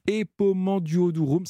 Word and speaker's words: Epomanduodurum: Epomanduodurum, ça y est, je suis prêt. Je suis très fier de prononcer ce mot correctement.